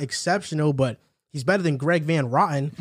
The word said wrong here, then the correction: Van Roten: He's better than Greg Van Roten. So yeah. my fault. I mispronounced his name.